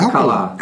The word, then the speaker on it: Alcala: I'm still pronouncing Alcala.